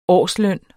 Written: årsløn

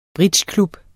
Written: bridgeklub

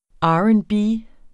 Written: R&B

